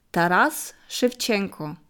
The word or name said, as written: Taras Schwetschenko